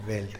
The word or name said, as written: beld